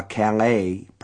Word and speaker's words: Calais: There is a Madrid, the natives pronounce it Madrid, a Sweden, an Etna, a Calais, pronounced so it rhymes with Dallas, a Cambridge, and a Frankfurt.